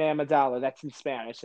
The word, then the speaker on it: Amidala: Okay, it's really similar. It's Padme. – Padme. – Amidala.